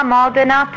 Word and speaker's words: Modena: Parma, Modena, Toscano, San Daniele.